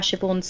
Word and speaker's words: Siobhan: It's like Siobhan. Think about how Siobhan's spelt. – Is that the S-I-B? – Blah, blah. S-I-O-B-H-A-N. – And it's pronounced what? – Siobhan. – Siobhan.